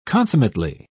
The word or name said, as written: consummately